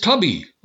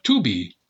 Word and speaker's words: Tubi: And there's Tubi, or Tubi. Well, it's spelled T-U-B-I.